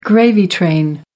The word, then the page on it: gravy train: American English